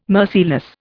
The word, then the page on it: merciless: Indian